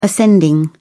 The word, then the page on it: ascending: British English